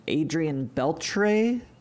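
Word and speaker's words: Adrian Beltré: So, for example, tell me if I'm saying this wrong, but Adrian Beltre? – Yep. Nailed it.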